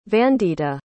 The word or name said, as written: vandita